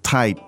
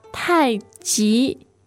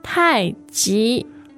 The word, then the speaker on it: Tai Chi: It's in Chinese pronunciation, it is not pronounced Tai Chi. It's not the CH sound. It's more soft sound, like a J sound.